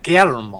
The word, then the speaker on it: Guillermo: Guillermo del Toro's Frankenstein. – Guillermo. – Yeah, you got to roll that R. – I love that. Guillermo.